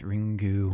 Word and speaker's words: Ringu: I think in Japanese, there's like an extra syllable at the end. It's either like Ringu or Ringa, but I believe it's Ringu. Ringu. I mean, it's spelled Ringu, but...